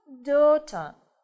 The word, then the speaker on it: daughter: Следующее слово – daughter. – Daughter. – Дочь. Посмотрите, да, как много здесь букв, а читается-то всего 4 звука.